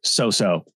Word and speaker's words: Cao Cao: Cao Cao is the general from Wei in Romance of the Three Kingdoms, but in Japan, they pronounce it So-So. So Cao Cao is So-So.